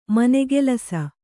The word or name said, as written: ಮನೆಗೆಲಸ